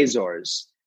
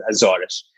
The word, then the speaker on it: Azores: The Azores is the in English we would say Azores, but Azores is a bastardization of the real pronunciation, which is Azores.